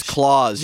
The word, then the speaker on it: Claus: No, but I pronounce claws with the S because the S is at the end of it.